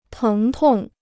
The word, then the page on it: 疼痛: téng tòng